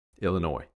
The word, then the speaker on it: Illinois: Illinois, yep.